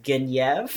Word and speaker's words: Guinevere: The other thing is that they all pronounce the names the French way. So Lancelot is Lancelot. Guinevere is Guinevere, which is fucked up.